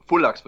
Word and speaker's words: Fullax: Fullax oder Fullax, wie genau wird euer Name denn jetzt ausgesprochen? – Fullax wird er ausgesprochen. Das ist eine nordhessische Mundart, die ausgestorben ist.